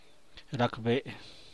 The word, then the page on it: rbinlaas: San Mateo Macuilxochitl Zapotec